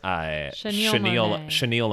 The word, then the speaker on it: chenille: And it's chenille O'Neill.